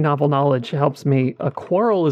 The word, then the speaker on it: Qorl: Oh, Q-O-R-L? – Yeah, Q-O-R-L. Quarrel? – Quarrel? – I think is probably the best way to pronounce that, but it is a little odd.